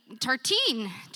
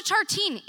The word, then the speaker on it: Tartine: A couple weeks ago, a new bakery opened up in Inner Sunset, Tartan. And am I even saying it right? Tartine. I have the cookbook. You think I'd know. Thank you. Now we all know. Go to Tartine.